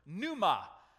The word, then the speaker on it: pneuma: it's the Greek word pneuma. The P here is silent.